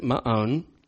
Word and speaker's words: Maon: That is not my own pronunciation of that word, by the way. That is the actual way to pronounce it, Maon.